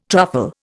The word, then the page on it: truffle: Indian